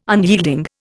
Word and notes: unyielding: Indian

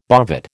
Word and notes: bovate: American English

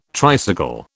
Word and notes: tricycle: British English

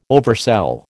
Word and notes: oversell: American English

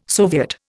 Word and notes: soviet: Indian

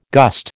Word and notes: gust: American English

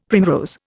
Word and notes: primrose: Indian

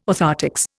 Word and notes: orthoptics: Indian

